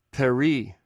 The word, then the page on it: peri: Greek